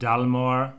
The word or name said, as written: Dalmore